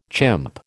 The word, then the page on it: chimp: American English